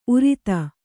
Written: ಉರ್ದು

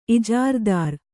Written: ಇಜಾರಾದಾರ

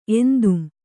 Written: ಎಂತುಂ

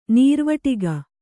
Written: ನೀರ್ವಟಿಗ

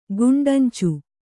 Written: ಗುಂಡಂಚು

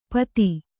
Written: ಪತಿ

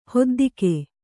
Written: ಹೊದ್ದಿಕೆ

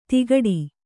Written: ತಿಗಟಿ

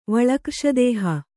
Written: ವಳಕ್ರ್ಷದೇಹ

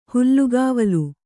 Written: ಹುಲ್ಲುಗಾವಲು

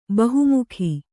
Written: ಬಹುಮುಖಿ

